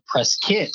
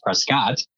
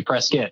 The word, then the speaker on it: Prescott: Prescott is how it's pronounced by the locals. Everybody else says Prescott. But if you ever visit, say Prescott. Otherwise, you'll get a local annoyingly correcting you.